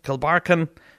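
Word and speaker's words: Kilbarchan: I still think I'm pronouncing that wrong. Is it a Kilbarkin? No, Kilbarkin is the correct way.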